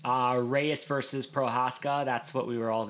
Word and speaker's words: Prochazka: Prochazka.